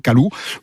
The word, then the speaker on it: calour: Alors ça s'écrit calour, mais on ne prononce pas le R final.